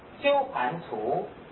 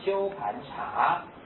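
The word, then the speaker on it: 鸠盘荼: 这时候假如你善男子善女人，发心要修普贤十大愿，那一切的魔君夜叉，这些是鬼神，罗刹鸠盘足，这个字注意一下，你可以念成鸠盘茶，这两个字都通。因为我去查过藏经，在一般都念鸠盘足，足是指茶叶的茶，上面还有一横。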